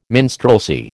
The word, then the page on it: minstrelsy: American English